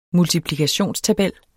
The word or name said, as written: multiplikationstabel